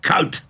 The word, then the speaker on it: Kuyt: So I found another Dutchman, and when the third Dutchman had confirmed the name, I felt confident in saying Dirk Kuyt. And there it was, Kuyt.